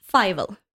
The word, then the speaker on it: Fivel: Nej, men då skulle jag säga att Fivel, var det så vi uttalade det? Ja.